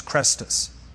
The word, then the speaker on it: Chrestus: And the riot, says this Roman historian, was over a man named Crestus, C-H-R-E-S-T-U-S, Crestus.